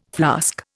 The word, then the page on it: flask: Indian